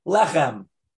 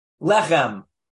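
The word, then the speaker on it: Lechem (לחם): No. Alan's got it. He mumbled it. Lechem. Lechem. Like we say, the blessing over bread. Hamotzi. – Lechem. – Lechem.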